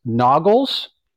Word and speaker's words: Nogales: It's the way everybody said it, but it's spelled like Nogales, right? But it's Noggles. Remember that? There was that Mexican food place. – Yeah, Noggles.